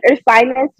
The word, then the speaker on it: Ursinus: Ursinus.